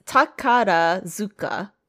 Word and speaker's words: Takarazuka: But yes, I think it's Tarakazu Revue. – Is the name of it? – Yeah, see, I just can't pronounce it. – I'm sorry. It's Takarazuka.